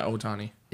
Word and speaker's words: Ohtani: The only problem with Ohtani, is that how you say his name? – I think so. Yeah, Ohtani.